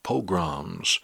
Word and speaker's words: pogrom: I'm not mispronouncing that. P-O-G-R-A-M, pogrom, for the purpose of wiping them out.